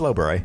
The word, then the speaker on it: Lobre: I just pronounced it Lobre, and you said Lobre. – It's Lobre. – It's like the La Brea tar pits.